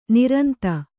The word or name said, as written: ನಿರಂತ